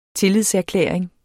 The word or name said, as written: tillidserklæring